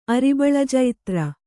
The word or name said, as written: ಅರಿಬಳಜೈತ್ರ